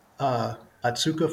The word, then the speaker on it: Otsuka: So you work with Otsuka Pharmaceutical Companies.